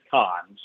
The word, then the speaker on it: Cannes: I know a lot of people pronounce it Cannes. The French pronounce it Cannes.